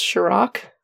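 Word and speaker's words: Cîroc: I don't remember when, but I think it's pronounced Ciroc. It's like C-I-R-O-C, but it's got like a little hat on top of the I.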